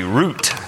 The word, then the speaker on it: Ruth: Her name in Hebrew is pronounced with a hard T, root, just like the root of a tree.